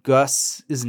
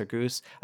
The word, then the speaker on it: Gus: somebody says, wait, like rhymes with like moose, which I think was meant to like settle that debate of whether it's Gus or Goose. I think it's supposed to be like goose, like it's got the umlaut U like German or something.